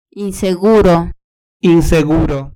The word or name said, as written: inseguro